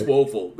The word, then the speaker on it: Woelfel: Did you say Wolfel or Woeful? – Wolfel. Woeful. It's literally W-O-E-L-F-E-L. – I'm just making Woeful. Sure. I'm making sure it wasn't like a mispronunciation. – No, it's Woeful.